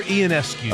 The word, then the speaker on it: Ionescu: From Oregon, the former number one, and I just butchered her name, didn't I? – Ionescu? – They call her Ionescu.